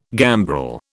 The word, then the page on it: gambrel: British English